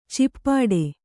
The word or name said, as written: ಚಿಪ್ಪಡೆ